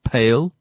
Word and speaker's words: Pale: It's spelled the same as the English word pale, you know, the word that means to be pale, but you have to pronounce it as Pale in terms of the place name.